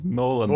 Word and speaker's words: Нолан: Когда Кристофер Нован, но, но, Нован, Нолан,